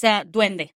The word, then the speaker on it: Duende: but is it Duende? – So it's Duende that we call it, Duende.